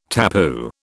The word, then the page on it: tapu: British English